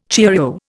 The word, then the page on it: cheerio: Indian